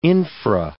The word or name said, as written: infra